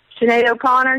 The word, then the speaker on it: Sinead O'Connor: Sinead O'Connor.